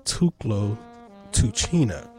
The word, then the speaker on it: Tuklo Tuchina: This is Charles Lamont Woods, and the name of his record is Tuklo Tuchina.